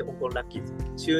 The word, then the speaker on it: 香港ラッキーズ: 香港好きな運と書いて香港ラッキーズ、中年不思議国と書いて中年ワンダーランド、こちらの方ですね、ぜひぜひ検索していただくと元気が出る曲が聴けますよ。